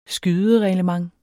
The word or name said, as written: skydereglement